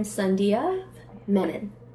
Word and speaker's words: Sandhya Menon: Sure. I'm Sandhya Menon. Yeah. I was saying Sandia, so I'm happy to know.